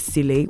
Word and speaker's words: Chley: It is S, you know how South Africans spell their name now, S-I-P-H-E-S-I-H-L-E, that's Sifen Sele. So the shorting form is the Sele, which is now stylized as C-H-L-E-Y, which makes it Englishized, but the pronunciation is still South African.